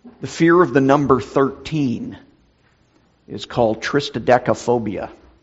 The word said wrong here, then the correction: triskaidekaphobia: Or I think I said that wrong, triskaidekaphobia.